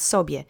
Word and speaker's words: sobie: So we've had się a lot this year, and now we've got sobie, which we've seen less frequently, but still. So it's just like the rain itself is falling. Let's say sobie together three times. Accent is on the first syllable.